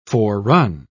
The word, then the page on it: forerun: British English